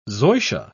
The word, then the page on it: zoysia: British English